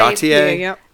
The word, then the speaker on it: Gaultier: Or I always say Gaultier.